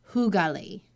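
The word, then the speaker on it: hygge: So it's pronounced hugali.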